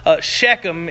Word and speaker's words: Shechem: He passed through the land, the place of Shechem is how it's pronounced.